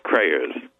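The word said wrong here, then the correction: Greyerz: Grayers. The G. – Yeah, that's right.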